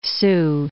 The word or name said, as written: Soo Canals